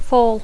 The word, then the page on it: fall: English